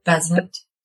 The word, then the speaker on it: Bezzant: Bezzant.